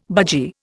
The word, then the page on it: budgie: Indian